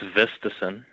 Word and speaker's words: Vistisen: It's Vistason, and Vistason.